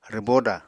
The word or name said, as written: rwbóda